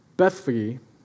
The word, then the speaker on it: Bethphage: As they approached Jerusalem and came to Beth, Bethphage is how it's technically pronounced, and Bethany at the Mount of Olives.